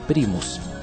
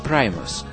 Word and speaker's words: Primus: Чтобы не путать американцев с первопроходцами отечественного рока, нашей российской группой «Примус», название этого заокеанского ансамбля иногда произносят на англосаксонский манер, как «Primus». Но это неверно, так как слово это заимствовано не из английского языка, а из латыни.